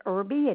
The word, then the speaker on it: Irby: The last name is Irby, and it's spelled I-R-B-Y.